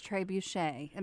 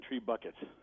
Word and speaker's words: trebuchet: But so what is trebuchet? Am I saying it right, too? – You actually are. There are three official pronunciation: trebuchet, trebuchet, and tree bucket.